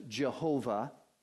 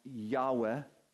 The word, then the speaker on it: Yahweh: And it's the term that popularly has been voiced as Jehovah. It's probably better pronounced Yahweh.